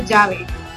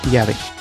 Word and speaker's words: llave: Pero ¿cómo pronuncias la doble L? Yo pronuncio, por ejemplo, llave, yo digo llave. – Sí. Nosotros llave. Como que fuera una Y.